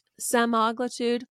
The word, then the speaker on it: semaglutide: I've been thinking about Ozempic, semaglutide.